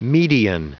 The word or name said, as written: median